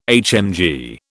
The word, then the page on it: HMG: British English